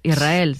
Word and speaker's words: Israel: Y la S con la R es difícil de pronunciar. La gente dice Israel, es verdad. – Que suene la S. Israel, sí, es difícil de pronunciar.